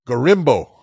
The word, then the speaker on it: Gorimbo: Next fight, Pete Rodriguez was taking on Themba Gormino. – Gorimbo? – Say it wrong. Gorimbo.